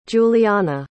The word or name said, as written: juliana